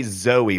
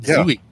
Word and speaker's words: Zooey: I think I was the person who started the Zooey trend because it's two O's instead of the usual Z-O-E for her name. So I said, I think she pronounces it Zooey. – Yeah. – Yes, she does. I'm saying it the way she says it.